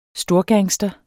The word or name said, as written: storgangster